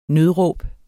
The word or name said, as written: nødråb